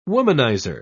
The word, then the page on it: womaniser: British English